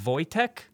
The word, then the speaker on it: Wojtek: He's going to talk to us about a hero named Corporal Wojtek.